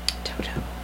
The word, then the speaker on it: Toto: See, because it's spelled T-O-T-O. – I'm sorry. Excuse me. – Because it's spelled T-O-T-O, they refuse to pronounce it Toto. – Toto. – And they always run. And here's a brand new one from Toto. It just doesn't sound right. See, it's Toto.